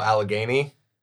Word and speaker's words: Allegheny: No, Allegheny.